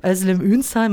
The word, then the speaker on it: Özlem Ünsal: Ja, mein Name hast du schon von vornherein richtig ausgesprochen. Perfekt. Da geht mir immer das Herz auf. Özlem Ünsal, meinem Namen nach, kann man natürlich schon entnehmen, auch ich habe einen sogenannten Migrationshintergrund, bin aber schon sehr, sehr lange in Deutschland.